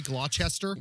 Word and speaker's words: Gloucester: Like really, Gloucester, Worcester.